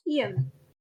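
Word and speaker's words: Eyam: And it's called Eam. That's how you pronounce it.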